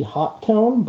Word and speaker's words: Hotone: I think it's supposed to be Hot Tone, but I always call it Hotone or Hot Tone.